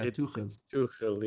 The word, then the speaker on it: Tuchel: Yeah, isn't that how you pronounce it? Thomas, I think that's the real Tuchel. Way to do it. Yeah, Tuchel. Yeah, it's like a G, it's like a H for American, like English.